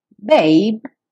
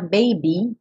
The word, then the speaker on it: babe: Babe, sem pronunciar o E, e cuidado para não falar baby, porque essa é outra palavra, uma variação.